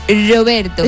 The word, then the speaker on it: Roberto: Mira, en primero de italiano nos enseñaron que la R, cuando era, venía sola, dilo. Era un Roberto. – Roberto. – O sea, se pronuncia como si fuera nuestra R suave.